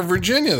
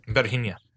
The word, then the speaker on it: Virginia: Well, he has a thing for Bette, but it's like, what about poor Virginia there? – Virginia. Say it right, please. – Virginia. – Thank you.